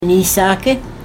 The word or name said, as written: niisaaki